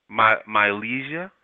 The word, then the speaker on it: Mylezia: Hey, what's going on, Mylezia? – Yeah. – I'm saying your name right? – Mylezia. – Mylezia? – Yeah.